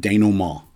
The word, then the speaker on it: denouement: And yes, Ted, that is how you say it, denouement.